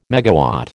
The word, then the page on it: megawatt: American English